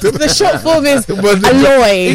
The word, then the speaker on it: Aloy: Anyway, I see A L O Y.